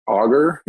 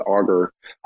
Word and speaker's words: Augur: Is it Augur? Yeah, that's how you pronounce it, Augur.